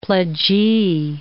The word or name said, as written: pledgee